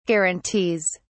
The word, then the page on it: guarantees: British English